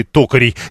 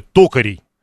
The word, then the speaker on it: токарей: И, кстати, про колледж и токарей. 933-й пишет, что ударение должно быть такое «токарей», а не «токарей». При этом существуют разные словари, которые допускают оба варианта. И токарей, и токарей.